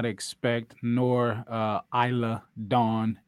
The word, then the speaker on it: Isla: but I guess the S is silent, and that's dumb. – Isla, the S is silent, stupid.